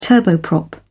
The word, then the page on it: turboprop: British English